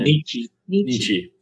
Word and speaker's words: Nietzsche: I forget how to say his name. – Nietzsche.